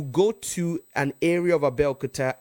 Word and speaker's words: Abeokuta: It's like going to Abeokuta and somebody says, now Abeokuta is a, Abeokuta, let me pronounce it correctly, Abeokuta is a city in the western region of Nigeria in a state called Ogun State.